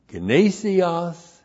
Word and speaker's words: gnesios: Three, the testing in our verse is for approval of the gnesios humeteros agape. There you go. It's for the approval of the gnesios humeteros agape. Gnesios G-N long E-S-I-O-S. Gnesios means genuineness. Genuineness. And then humeteros agape. That's H-U-M-E-T-E-R-O-S, humeteros.